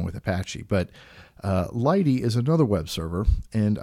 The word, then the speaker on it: lighttpd: Lighty is spelled L-I-G-H-T-T-P-D, and so they pronounce it Lighty, and it's a much smaller piece of software.